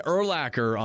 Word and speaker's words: Urlacher: We're going to have Brian Urlacher on the program. Yeah, I said it right. You guys think I'm messing with you. Hall of Famer Brian Urlacher.